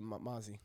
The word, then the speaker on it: Mozzy: Not Lil Mosey. – No, just M-O-Z-Z-Y. – I think, Mosey. – Mosey. They dropped a single called Bumped into Oak Park. – Who did? – YG and Mosey.